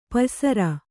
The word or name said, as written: ಪಯ್ಸರ